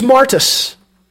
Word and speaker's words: martus: There's a second thing that we want to remember, and that is that that Greek word that we translate as witness, well, the Greek pronunciation, this will give you the clue, you know this, is martus.